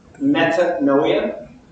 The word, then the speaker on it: Metanoia: I don't know how to say it, but we just pronounce it noia. Metanoia. That's how you say it in English.